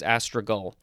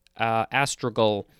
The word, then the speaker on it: astragal: I think it's astragal. A-S-T-R-A-G-A-L. This is a noun from 1563. If I didn't look at the pronunciation guide, I'd probably want to say astragal because it's with a G-A-L at the end.